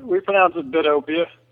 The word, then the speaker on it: Bidopia: We pronounce it Bidopia. – Okay. – Kind of like a derivative of Utopia.